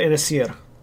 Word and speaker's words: Inasir: Yeah, Daira Inasir. The first A is silent. The first A in Inasir is, I should say.